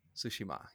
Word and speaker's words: Tsushima: Am I saying it right? – Tsushima. – Yeah, there you go.